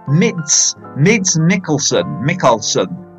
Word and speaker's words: Mads Mikkelsen: Okay, movie tip, definitely Mid Mackelson. Have I got his name right there? You have to help me out there. Mids Mackelson Mickelson.